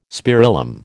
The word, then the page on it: spirillum: American English